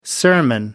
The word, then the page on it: sermon: American English